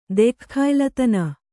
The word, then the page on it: ದೆಖ್ಖಾಯ್ಲತನ: kannada